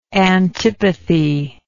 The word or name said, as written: antipathist